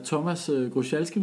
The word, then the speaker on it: Thomas Grochalski: I dag der sidder vi hos Johan og hygger, og vi har Thomas Grochalski med her. Er det udtalt korrekt? – Det er det.